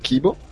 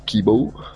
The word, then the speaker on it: Kibo: Gli obiettivi della missione sono l'assemblaggio del primo segmento del modulo giapponese, Kibo, o Kibou, dipende quanto si vuole seguire la pronuncia giapponese,